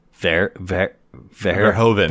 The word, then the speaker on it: Verhoeven: Verhoeven. – Verhoeven.